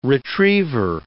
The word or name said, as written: retriever